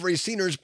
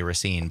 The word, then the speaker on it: Racine: Also had the smartness and the quickness to call out Racine, Wisconsin, and knows how native Raciners pronounce it even. – Even other people around the state often say Racine, but right. Racine, if you live there, is how you say it.